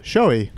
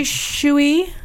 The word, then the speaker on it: Shoei: S-H-O-E-I. – Showy. – That is correct. – All right. – Ding, a lot ding, of people ding, say shooey. – Yep. – Or shawai is showy. All right, point. All right, bagel. – Yes. – A-K-R-A-P-O-V-I-C.